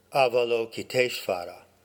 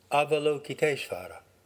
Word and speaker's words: Avalokiteshvara: Avalokiteshvara would be an approximate American English pronunciation. Avalokiteshvara.